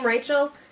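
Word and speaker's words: Reichl: Yeah, and I mean, I don't know if you saw Ruth Reichel. Is that how you pronounce her name, Reichel? – Yeah, she called for a new women's movement on a TV show.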